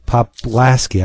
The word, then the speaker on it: Poplaski: Spiegelman, Gilbert Shelton, oh, Trina Robbins, Peter Popalski, Poplaski, I always mispronounce that.